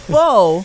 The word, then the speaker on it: pho: Actually, it's pronounced pho.